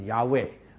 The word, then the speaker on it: Yahweh: But the real pronunciation we all realize is Yahweh.